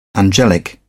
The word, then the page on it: angelic: British English